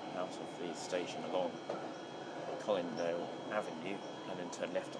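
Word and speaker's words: Colindale: The next station is Collindale. – So I am pronouncing it right, that's good.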